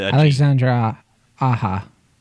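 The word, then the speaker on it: Alexandre Aja: I think Alexandre Ahi, is that how you pronounce his name? Or is it Alexandre Aha. – Aha. Okay. So it's, I thought it was A-G-E. Is it A-J-A? – It's A-J-A. – Oh, okay. For whatever reason, I thought it was A-G-E.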